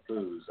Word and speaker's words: Booz: I pronounce it right every day. I never forget Booz, as I like to say.